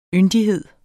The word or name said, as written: yndighed